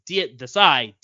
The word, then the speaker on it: Desai: The code is Desai, D-E-S-A-I.